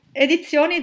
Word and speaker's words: Edizioni: it's called Edizioni Sur, right? I probably said it wrong, but No, it's you say it good. Edizioni, it's just that the Z is a Z, but Edizioni Sur. Anyway, yes, these are two small independent presses from Italy.